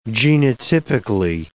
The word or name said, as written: genotypically